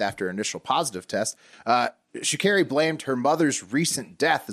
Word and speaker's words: Sha'Carri: I think it's Sha'Carri. I looked up the pronunciation.